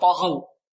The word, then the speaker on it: طغوا: وفرعون ذي الأوتاد الذين طغوا، الطاء هنا مفخمة والغين كذلك مفخمة واللواء مرقة، طغوا طغوا، لأن حروف الاستيلاء كم حرف قلنا؟